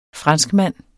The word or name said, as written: FRANSKMAND